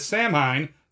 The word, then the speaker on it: Samhain: Now, for those of you listening at home, Samhain is spelled S-A-M-H-A-I-N. So you probably pronounced it as Samhain. That is not correct. It is technically pronounced Samhain.